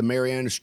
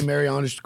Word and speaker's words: Marianas: Did I say Marianas correctly? Did I pronounce that word correctly? – I believe so, yeah.